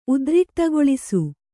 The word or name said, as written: ಉದ್ರಿಕ್ತಗೊಳಿಸು